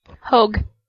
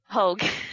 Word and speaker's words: Hoge: The other day, and he's in Nashville writing now with Josh Hoge. – Hogue. – Hogue. – Sorry. We've been saying his name wrong.